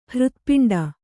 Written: ಹೃತ್ಪಿಂಡ

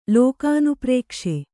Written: ಲೋಕಾನುಪ್ರೇಕ್ಷೆ

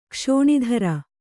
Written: ಕ್ಷೋಣೀಧ್ರ

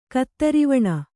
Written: ಕೇದಾರವನ

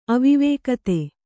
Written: ಅವಿವೇಕತೆ

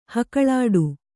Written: ಹೊಗಳಾಟ

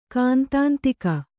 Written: ಕಾಂತಾಂತಿಕ

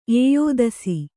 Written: ಎಯ್ದಿಸು

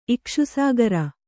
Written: ಇಕ್ಷುಸಾಗರ

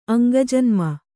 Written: ಅಂಗಜನ್ಮ

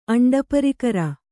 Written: ಅಂಡಪರಿಕರ

